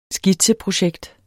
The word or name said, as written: skitseprojekt